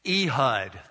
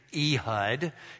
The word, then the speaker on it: Ehud: Ehud, that's how you say his name, Ehud.